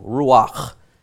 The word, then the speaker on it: ruach: So ruach is what we get from these three.